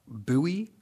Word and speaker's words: Bowie: Here in Maryland, it is pronounced Bowie. – Bowie. Like Bowie. – Ghost. Boo.